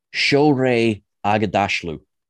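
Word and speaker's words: Shohreh Aghdashloo: That's why it's only, well, not the only reason, but it's only Sharia and, I think I've pronounced that correctly, I think it's Shreya. Oh, I really don't want to get this wrong because she has such a wonderful name. And I had to learn it for one of the Trek Culture videos. I'm gonna do this right. She deserves to have her name pronounced correctly. There we go. Shorey Agadashlu.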